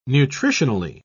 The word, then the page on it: nutritionally: British English